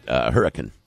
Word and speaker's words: Hurricane: Say the R most of the time. Or the I. It's Hurricane. – Okay. – It's Hurricane.